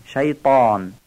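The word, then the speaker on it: شيطان: حرف سوم تای دسته داره. ما در فارسی تای دسته دارم تی نقطه دار تلفظ میکنیم، یعنی میگیم شیطان. در قرائت عربی و قرآن و نماز باید این بارو درشت و پر از گلو بگیم، شیطان.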